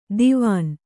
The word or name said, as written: ದಿವಾನಾ